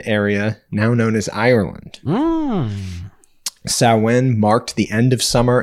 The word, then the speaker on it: Samhain: Now, you may have seen this word before, and you may have heard it in another pronunciation because it's spelled Samhain. Samhain. – Okay. – So there's a band, Glenn Danzig had a band called Samhain. But it's actually pronounced Samhain.